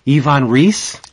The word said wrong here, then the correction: Reis: Yes. – No, Ivan Heiss. – Yes. – Oh, it's Heiss. Why do I get it wrong?